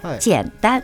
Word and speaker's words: 简单: 这个太简单了。 – 太简、ね、简单了。これはね、うん、難しいは簡単と漢字全く一緒。 – あ、そうなんですか。 – はい、ただ発音は简单。